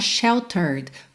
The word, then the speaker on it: sheltered: Bom, eu vou começar pelo aquele que não é mesmo para ser pronunciado, que é o último E da palavra sheltered. Como a gente viu, sheltered é o passado de shelter. A gente coloca ED para fazer o passado, mas o E não é pronunciado, tá bem?